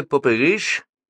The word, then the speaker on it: пупыриш: — А кто такой пупыриш? – — поинтересовалась Вера, сделав ударение на последнем слоге.